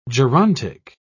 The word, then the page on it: gerontic: British English